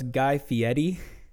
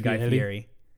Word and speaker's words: Fieri: So it's Guy Fieri, but I always say Fieri.